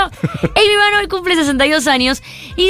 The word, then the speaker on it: Aimee Mann: Amy Mann. Chicos, toman nota, ¿eh? Se escribe Aime con dos E y Mann con dos N.